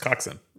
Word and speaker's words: coxswain: Well, it's spelled COCKS space WAYNE. – Uh-huh. And it's pronounced coxswain.